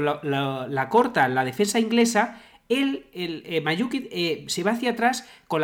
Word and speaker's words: Mandžukić: Vale, pues el gol de Croacia, el de Mandžukić, ¿cómo se pronuncia? – Sí, Mandžukić, sí. – Mandžukić, sí.